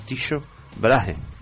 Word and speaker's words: Tycho Brahe: Hemos estado viendo cómo se pronunciaba en alemán y en latín. – Sí. – Y es Tycho Brahe.